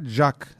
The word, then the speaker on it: Jacques: Tabi sondaki heceyi atıyorlar. Jack diyor Fransızlar. İngilizler de Fransızcadan aynen alıp Jack demişler. Ama böyle açık Jack gibi değil de O'ya yakın koyu bir telaffuzla. Yani Jack, Jack, Jack bu tarz bir telaffuzu var.